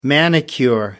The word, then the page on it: manicure: American English